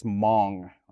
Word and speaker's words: Hmong: My ethnicity is Hmong, right? Spelled H-M-O-N-G. The H is silent, right?